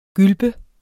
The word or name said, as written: GYLPE